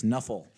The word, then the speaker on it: Nuffle: And being that this is a football game, it is a mispronunciation of the letters NFL. It's Nuffle.